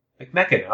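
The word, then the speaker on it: McMechen: So he talked about living with his aunt and uncle in, I should have looked up how to pronounce this, McMecken, pronunciation. McMecken? McMecken? I don't know. McMecken. All right, I was right.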